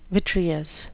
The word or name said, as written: vitreous